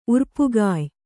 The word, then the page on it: ಉರ್ಪುಗಾಯ್: urpugāy